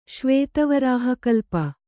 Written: ಶ್ವೇತವರಾಹಕಲ್ಪ

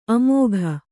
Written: ಆಮೋಘ

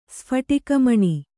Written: ಸ್ಫಟಿಕಮಣಿ

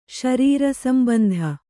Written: ಶರೀರಸಂಬಂಧ

